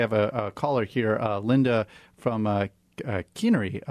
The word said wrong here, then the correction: Kearney: Well, locally it's pronounced Kearney.